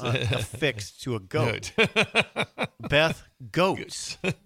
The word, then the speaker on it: Goetz: I called her Beth Goetz. – Beth Goetz. – Oh, it's spelled G-O-E-T-Z. I don't know Beth Goetz very well. I haven't heard her name out loud. It's Beth Goetz.